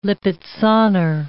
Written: Lipizzaner

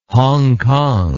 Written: Hong Kong

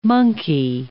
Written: monkey

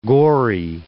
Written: gory

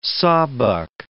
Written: sawbuck

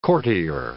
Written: courtier

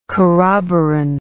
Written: corroborant

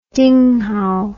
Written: dinghow

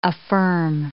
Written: affirm

